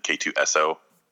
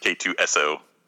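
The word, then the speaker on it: K-2SO: I was thinking, too, that since they actually spelled out his name finally, so we know how to pronounce it, K2SO, I think it's like, I love you so.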